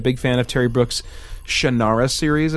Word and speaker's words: Shannara: Oh, yeah, and I should mention I mispronounced, I think it's pronounced Shannara.